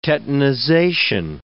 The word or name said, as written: tetanization